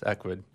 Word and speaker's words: Ecwid: And that's kind of how I came across Equid.